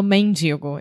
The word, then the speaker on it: mendigo: Eu não falo mendigo, eu falo mendigo. – Como assim? Qual a diferença? – A palavra se escreve com E. M-E-N-D-I-G-O. Eu, com meu sotaque, eu falo mendigo. Ao hum. invés de mendigo.